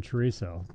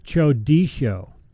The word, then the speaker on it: chorizo: They told me it's not pronounced chorizo. – Chorizo. – It's not pronounced chorizo. – What? How is it pronounced? – He told me it's pronounced chodisho.